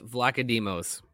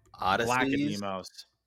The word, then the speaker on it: Vlachodimos: I believe it is Odysseus Valachiadimos. – Odysseus Vlachodimos. – Vlachodimos.